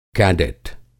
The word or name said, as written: कैडेट